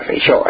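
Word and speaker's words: Feijóo: Pero claro, si el Feijó fuera un poquillo listiño, quieto, bueno, ti no sabes pronunciar, porque esa palabra es galega, esa palabra es galega, tienes que pronunciar Feijó, ¿eh?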